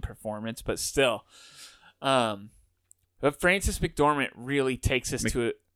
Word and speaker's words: McDormand: Well, that sounded like McDormand. – There you go. – Okay. – You said it right that time.